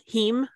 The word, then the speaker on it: heme: The Impossible Burger has a heme. How do you say it? Heme? – Oh, heme. – Heme. – H-E-M-E? – Yes.